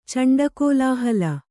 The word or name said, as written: ಚಂಡಕೋಲಾಹಲ